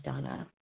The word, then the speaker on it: Dana: This is Donna B. I'm a recovered compulsive overeater and bulimic, calling from Denver, Colorado. My name is spelled D-A-N-A, but it's pronounced Donna.